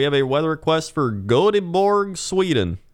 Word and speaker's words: Göteborg: So, the pronunciation, Göteborg, yes? That's what it's spelled? – Yeah. – And I guess it's also G-O-T-H, Gothenburg? Gothenburg? – Gothenburg. – But according to YouTube, according to Google, the pronunciation in America is goat bog.